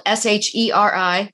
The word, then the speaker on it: Sheri: My name is spelled Sheri, one R, and fits like your shoe fits with two T's.